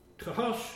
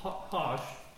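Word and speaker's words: hash: Mehar, Shalal, Kosh, it's actually a hard C-H sound, but Hosh, H in the softer term, and Baz.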